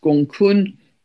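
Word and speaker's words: Gonggong: We also have the dwarf planet Gongong, which I squeezed in, which is spelt all one word, Gongong, pronounced Gongkun, 6 degrees of Pisces, very tightly conjunct the North Node, our collective soul's path of growth.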